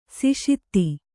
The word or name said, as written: ಸಿಷಿತ್ತಿ